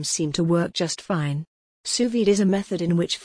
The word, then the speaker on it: sous vide: Sous vide, pronounced sous vide, is French for under vacuum.